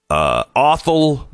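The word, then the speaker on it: Othal: The suspected shooter is Awful Wallace. Rhymes with waffle.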